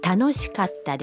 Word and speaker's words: 楽しかった: Katta se pronuncia haciendo una breve pausa entre kat y ta. – Tanoshikatta desu.